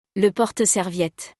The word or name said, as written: Le porte-serviette